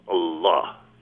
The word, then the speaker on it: Allah: Even the word for God with a small g is ilah, and Allah is the, so it's differently pronounced. And it's probably the definite article, El, combined with Ilah, but it becomes Allah, finally.